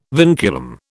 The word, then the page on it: vinculum: British English